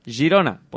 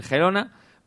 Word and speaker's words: Girona: Spar Girona. Esto se dice como suena, Spar Girona. Pueden decir Gerona, pero queda mejor decir Girona, que es como se llama el equipo, de hecho.